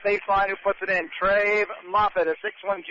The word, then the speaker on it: Treve: T-R-E-V-E. That's how you spell his first name. I'm pronouncing it Trave.